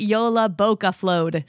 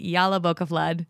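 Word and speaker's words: Jólabókaflóð: she also said they pronounce it Yola Boca Flowed. So there we go. I'm pretty sure I announced it incorrectly through the entire episode because I was calling it Yala Boca Flood.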